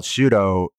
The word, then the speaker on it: Shuto: The reason why it's called Shuto is because it's the Japanese pronunciation of shoot.